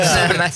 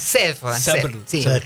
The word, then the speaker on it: Sèvres: No, no sé pronunciarlo, pero ahí el acentito, le erraste con el acento. Sèvres. – Sèvres.